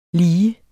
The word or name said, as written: lige